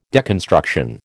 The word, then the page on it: deconstruction: American English